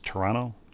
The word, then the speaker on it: Toronto: And as we were frequently reminded during the finals, if you're from Toronto, you don't pronounce the second T. It's Toronto, generally.